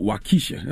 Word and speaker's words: Waukesha: Now you got a song on the album called Waukesha.